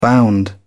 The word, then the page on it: bound: British English